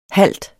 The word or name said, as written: halt